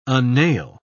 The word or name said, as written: unnail